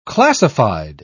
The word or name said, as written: classified